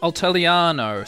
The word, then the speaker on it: Altaliano: is now uh, a Altaliano, spelled like Italiano, but Alt Altaliano.